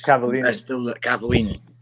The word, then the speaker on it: Cavallini: Last week when we had Lucas Cavanelli on, and we asked mm-hmm. him his Cavallini.